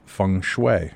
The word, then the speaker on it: feng shui: This evening, we're talking to our guest, David Weiss, and we're talking to him about the ancient art of feng shui. Am I pronouncing that correctly, David? – Yes, you are.